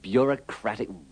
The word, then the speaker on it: bureaucratic: In bureaucracy, the EAU is pronounced like the O in got, but in bureaucratic and bureaucrat, it is pronounced with a schwa.